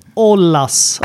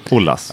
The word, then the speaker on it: Ollas: Nej, Gustav Ollas. Olas. – Olas. O-L-L-A-S, eller hur? – Ollas, ja. Precis. – Välkommen. – Tack. – Hur uttalar man ditt efternamn? – Ja, men det är Ollas.